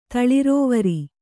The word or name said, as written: ತಳಿರೋವರಿ